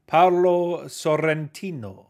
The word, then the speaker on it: Paolo Sorrentino: How do you say his name? Paolo Sorrentino. – Paolo Sorrentino. – Paolo Sorrentino. – That's it. Very good.